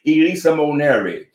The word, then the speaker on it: eleemosynary: And there's a word, let me spell it for you, Elisa Moneri.